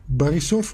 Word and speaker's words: Borisov: Eh, ik heb ook begrepen trouwens dat eh, de Bulgaarse premier Boyko Borisov, Barisov ook een rolletje Borisov. in deze clip. – Ja, volgens mij spreek je het heel erg op zijn Russisch uit.